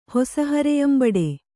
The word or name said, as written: ಹೊಸಹರೆಯಂಬಡೆ